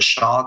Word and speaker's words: Shaw: Our company's name is Invest with Shaw.